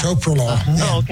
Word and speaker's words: Metoprolol: The other medicine they put me on, I'm trying to remember how to pronounce it, Metoprolol. – Yeah. Metoprolol.